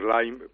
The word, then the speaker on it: Lyme: Tenemos que decir Lyme o Lyme, ¿cómo se pronuncia esto? – Bueno, se puede decir de las dos maneras.